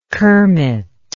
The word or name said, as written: kermit